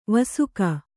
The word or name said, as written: ವಾಸಕ